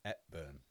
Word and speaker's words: Hepburn: Hepburn. – Why are you pronouncing the H? Hepburn. – Hepburn for you Londoners.